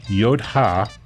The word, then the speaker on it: Yodha: The Tata Yodha. – Spell that. – Y-O-D-H-A.